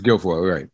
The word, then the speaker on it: Guilfoyle: It's Guilfoyle, right.